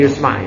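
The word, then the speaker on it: yusma' (يُسْمَع): Dibacanya yusma' ya, sautun ya, naibul fa'il.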